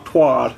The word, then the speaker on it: twat: Do Americans pronounce it twat? Twad. – Do they? – Yeah, I think I've heard that. – Is it even a word over there? – Yeah, I've heard them say twad.